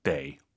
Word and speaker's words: 得: 59페이지 데이 어떨 때는 더. 사실은 발음이 또 있어요. 근데 오늘은 두 개만 해보시죠. 아무리 강조해도 지나치지 않습니다. 수러, 즉 동사나 형용사 앞에 있으면 더가 아니라 데이입니다.